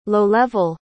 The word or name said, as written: low-level